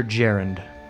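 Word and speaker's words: Gerund: Is it Garand or Jarend? – Jarend. Jarend and Gunvald. – Just like the grammatical term? – Yes. – Okay. – Is that how it's spelled, too? – No. – J-R-U-N-D? No, it's G-E-R-U-N-D.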